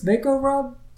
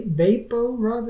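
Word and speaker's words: VapoRub: Uh, but the, the visual reveal of how uh, jack knows she's lying and she's using the same it looks like vicks vaco rub Va- vapo rub is how you say that not vaco rub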